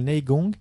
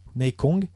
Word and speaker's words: Neigong: Donc, dans le Neigong, Neikong, dépend comment on le prononce, on a cette tentative d'aller puiser justement dans les forces énergétiques, hein.